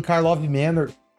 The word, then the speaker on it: Karlov Manor: Em Karlov Manor, eu falei certo.